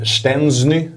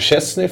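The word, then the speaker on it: Szczęsny: Vi har ju en sån situation på Simor där eh, min tidigare kollega Jesper Husfeldt körde 'stensny' vilket är liksom korrekt polskt uttal, inget fel med det. Jag kör fortfarande kvar på Chesney för att jag tycker att det känns rätt för mig att säga det. Hur, hur, vad känner du om den här?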